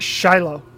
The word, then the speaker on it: Xolo: XOLO. XOLO is his first name. Hmm. It's pronounced Shiloh. – Oh, that's cool. – Yeah, really cool. I believe it's either Shiloh or Sholo.